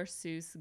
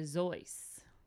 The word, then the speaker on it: Seuss: His real name is Theodore Seuss Geisel. – Geisel. – It's actually not pronounced Seuss, but rather Zeuss.